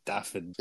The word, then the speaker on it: Dafydd: I know Dafydd. Griffith. – Yeah, it's kind of like David, but it's Dafydd.